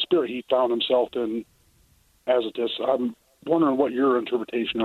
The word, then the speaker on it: Azotus: Azotus, if I'm saying that right. – Azotus, uh-huh.